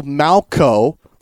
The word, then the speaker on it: Malchow: Okay, so I've just been told I got Marshall's name wrong. I'm sorry about that. It's Marshall Malchow, like Shane Falco from that football movie back in the day.